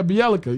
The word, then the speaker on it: Bjelica: Nemanja Bjelica. – Oh yeah. – Is that right? – Yeah, I'm doing that phonetically. – Yeah, Bjelica.